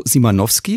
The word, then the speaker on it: Simanowski: Das erste heißt Data Love von Roberto Simanowski. Ist das richtig ausgesprochen? – Ja, das ist auch ein Deutscher.